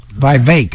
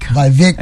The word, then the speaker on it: Vivek: Vivek Ramaswamy. Is that how you say it? – Vivek. – Vivek. – As in cake.